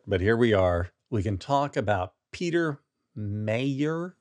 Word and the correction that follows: Meijer: Meyer. – Who can say? – Meyer.